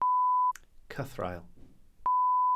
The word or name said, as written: cythraul